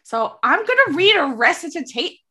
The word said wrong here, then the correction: recitation: Is that how you say it? – Recitation. – Recitation. Add that to my mispronounced words.